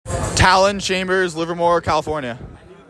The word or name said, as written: Tallon Chambers